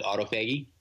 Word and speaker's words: autophagy: but autophagy? – Autophagy, right.